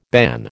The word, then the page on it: ban: American English